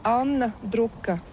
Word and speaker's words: Anna Druka: Oui, en letton, les voyelles sont un peu plus profondes et l'accent est toujours sur la première syllabe. C'est la grande différence avec la langue française. Ça fait une difficulté. Mais voilà, mais sinon, je m'appelle Anna Druka.